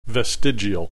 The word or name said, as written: vestigial